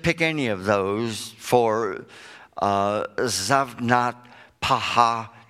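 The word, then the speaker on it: Zaphnath-Paaneah: Pharaoh gave Joseph the name Zaphnath-Paha'ah. I know what you're thinking. That's easy for you to say. But that was his name. Zaphnath-Paha'ah is the way you pronounce it, which means the abundance of life.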